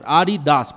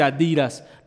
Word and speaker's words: Adidas: Se llama Ari Dassler. Ari Das. Por eso se dice así. No se dice Adidas.